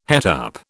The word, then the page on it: het up: British English